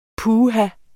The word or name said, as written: puha